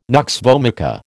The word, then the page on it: nux vomica: American English